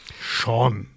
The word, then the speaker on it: Sean: Das ist John Travolta als Jean Archer Sean.